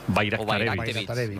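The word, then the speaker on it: Bajraktarević: Bayraktarevich. O sea, la J la conviertes en ahí, Bayraktarevich.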